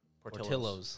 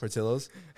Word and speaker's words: Portillo's: No, Petrillo's or Petrillo's. – Portillo's. – Portillo's.